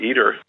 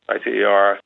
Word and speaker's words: ITER: I think the cost for ITER, we pronounce it ITER, ITER, is stabilizing.